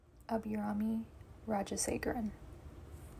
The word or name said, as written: Abirami Rajasegaran